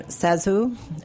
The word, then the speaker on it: ZaSu: Oh, so she actually pronounces her name ZaSu, which is totally counterintuitive, but she was a silent screen star who had these enormous, enormous eyes and sort of a pouty face.